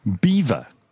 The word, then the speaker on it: Belvoir: B-E-L-V-O-I-R. – B-E-L-V-O-I-R. – Beaver Castle. – Belvoir. – Belvoir. – Belvoir. Right. – In French, Belvoir. In English, Beaver.